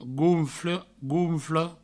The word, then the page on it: gonfle, gonfla: gounfle; gounflo